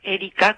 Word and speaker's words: Erika: Erika con K, en sí.